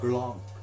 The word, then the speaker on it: Guerlain: Pronounce Guerlain. Guerlain. – It's in the throat. – Guerlain. – There you go.